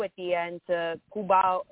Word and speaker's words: Cuba: You know, I should be saying Cuba. That's the correct way to say it, but I'm saying it my anglicized way.